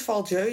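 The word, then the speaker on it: Val-Dieu: de Valjeu, spreek je het uit als Valjeu, ja toch? – Ja.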